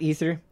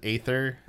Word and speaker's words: Aether: Is it Aether, not Ether? My bad. – Uh, everyone in game pronounces it Aether.